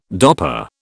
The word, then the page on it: Dopper: British English